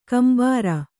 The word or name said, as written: ಕೆಂಬೂರ